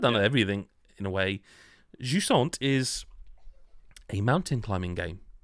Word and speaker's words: Jusant: Jusant. And that is how you pronounce it, I've checked. – That's how I would have pronounced it as well. – Jusant. It's a French word, Jusant.